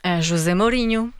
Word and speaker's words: José Mourinho: Comment on prononce José Mourinho en portugais? – José Mourinho. – Voilà, c'est ça.